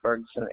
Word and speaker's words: Ferguson: Ruby, R-U-B-Y Ferguson, F-E-R-G-U-S-O-N.